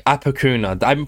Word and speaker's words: Apokuna: Apokuna. I feel like, oh, I'm saying it wrong. – You have said it so much better than most people do the first time.